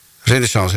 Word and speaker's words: Renaissance: Ja, Renaissance, zeg ik dat goed? Ja. Renaissance Records, ja.